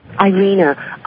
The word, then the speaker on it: Irena: Irina, I-R-E-N-A.